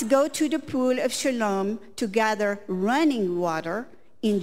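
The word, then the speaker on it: Shiloach: If I say it right, Shalom? – Shalom, yeah. – Shalom. – In Hebrew, Shaloach.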